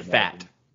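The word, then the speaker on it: Pfaadt: Brandon, is it, you might know, is it with the P, is it a fat? – Fat? – Or, yeah, you don't pronounce the P. – Brandon Fat.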